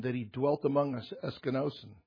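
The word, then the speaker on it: eskenosen (ἐσκήνωσεν): It's pronounced eskenosen, or eskenosen, eskenosen.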